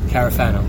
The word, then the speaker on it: Carafano: And then we had James, how do you pronounce it? It's Carafano or something? – Yes, Carafano.